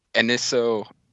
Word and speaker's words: anisotropy: Anastropy. Anastropy. – Anastropy, yeah. – Anastropy. – No, anisotropy. – Anisotropy. There's another... Oh, I fucking missed a syllable.